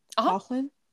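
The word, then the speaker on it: Coughlan: Coughlin? – Yeah, Okay.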